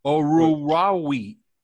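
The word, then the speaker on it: Oruwariye: In fact, let me go get his pronunciation. It is Orawara. Orawara. – That's how you say his name.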